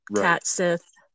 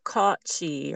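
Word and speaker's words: Cat Sìth: I think it's pronounced she. It looks like it's pronounced. – Yeah, I think it said to pronounce it like caught she or something like that. Yeah, caught she.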